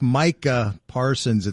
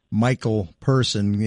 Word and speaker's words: Micah Parsons: Like, it's Micah Parsons. It's not Michael Person.